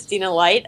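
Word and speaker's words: Stina Leicht: My name is Stina Light.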